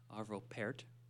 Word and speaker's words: Arvo Pärt: Of course, we all know the indomitable Arvo Pärt, as Bill Motlong has advised me to pronounce it.